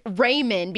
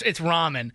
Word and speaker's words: ramen: Since Caitlin tweeted us at Joe and Alex Joe, she said, I pronounce it ramen because of the spelling. – I think if you didn't know, but it's ramen.